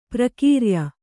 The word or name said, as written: ಪ್ರಕ್ರಯ